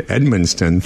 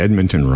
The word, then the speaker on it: Edmundston: But everybody I tell that I'm going to Edmundston thinks I'm pronouncing Edmonton wrong. – They want to correct. – They go, that's Edmonton.